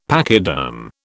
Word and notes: pachyderm: British English

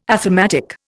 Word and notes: athematic: Indian

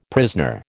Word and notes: prisoner: American English

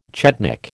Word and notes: chetnik: American English

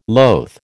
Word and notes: loth: American English

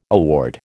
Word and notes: award: American English

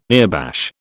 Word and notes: earbash: British English

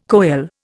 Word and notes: koel: Indian

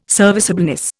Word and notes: serviceableness: Indian

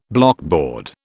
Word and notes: blockboard: British English

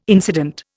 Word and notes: incident: Indian

